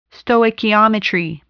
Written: stoichiometry

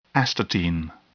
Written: astatine

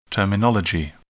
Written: terminology

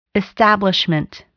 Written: establishment

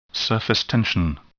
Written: surface tension

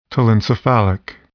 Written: telencephalic

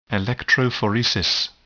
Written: electrophoresis

